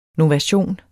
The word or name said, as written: NOVATIONEN